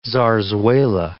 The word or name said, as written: zarzuela